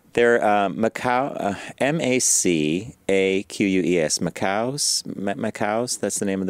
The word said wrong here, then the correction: macaque: Is that how you pronounce that, Garrett? – I thought it was macaque. – Macaque. You know, you're right.